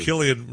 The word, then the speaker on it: Cillian: Cillian Murphy, yeah.